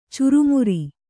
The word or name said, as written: ಚುರುಮರಿ